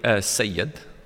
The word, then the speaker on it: Sayed: So generally kind of is pronounced Saeed. So my mum says Saeed, it's a bit easier. – Would it be Sayed? – Saeed, but it'd be Sayed.